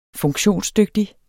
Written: funktionsdygtig

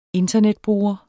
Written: internetbruger

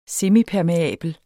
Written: semipermeabel

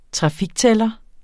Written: trafiktæller

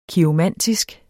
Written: kiromantisk

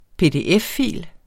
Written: PDF-fil

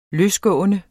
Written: løsgående

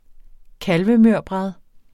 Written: kalvemørbrad

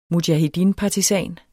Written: mujahedinpartisan

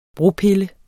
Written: bropille

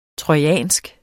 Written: trojansk

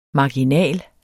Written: marginal

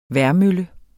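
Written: vejrmølle

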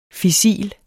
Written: fissil

